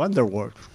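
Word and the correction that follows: Bandersnatch: No, Vanderwatch no. Ya lo he dicho mal. Vandersnatch.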